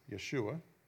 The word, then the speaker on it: Yeshua: The Hebrew word salvation means deliverance and is pronounced as Yeshua.